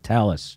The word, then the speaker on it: Thales: I thought it was pronounced Thales. Talis. They pronounce it Talis.